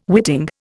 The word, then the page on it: witting: Indian